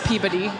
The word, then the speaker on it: Peabody: It's spelled Peebuddy is how it sounds. – Yes, Peabody. That's the one.